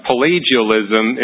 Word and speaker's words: Pelagianism: I have a hard time pronouncing it. It's Pelagianism.